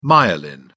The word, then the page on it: myelin: British English